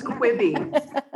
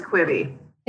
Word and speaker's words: Quibi: It's pronounced, Quibi. It's pronounced Quibi.